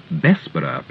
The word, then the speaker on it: Bessborough: Send today for the free Warner's Holiday Book to Warner's Holiday Camps, Radio 390, 35A Bessborough Place, and that's spelled B-E-S-S-B-O-R-O-U-G-H Place, London, Southwest 1.